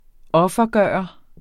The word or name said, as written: offergøre